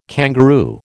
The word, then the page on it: kangaroo: American English